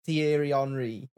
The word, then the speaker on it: Thierry Henry: Sure, he said Terry Henry. – Yeah. – Yeah, he did. Henry. – I am aware that it's Thierry Henry, okay? – Thank you. Say it properly. Put some respect on his name.